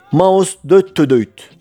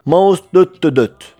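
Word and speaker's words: Maustetytöt: Harika Finlandiyalı ikili hatta harika Fin ikili Maus Döttödöyt ya da Maus Döttödöt ya da Maus Döttödöyt olarak telaffuz edilen ve Fince anlamı Spice Girls yani baharat kızlar olan ikiliden son bir şarkı dinliyoruz ve bu programımızın da sonuna geliyoruz.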